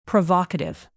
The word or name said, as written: provocative